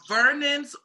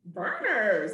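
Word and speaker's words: Vernors: Vernon's or Seagram's? – Verner's.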